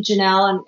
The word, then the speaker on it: Jenelle: It's just my name, Janelle, and it's spelled incorrectly sometimes because I spell it weird, or I should say my parents did. J-E-N-E-L-L-E. So Janelle Summers on Instagram,